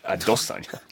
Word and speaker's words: Adesanya: Adesanya, I think, is the way he pronounces it properly. Okay. Like an American girl's Adesanya. But it's Adesanya.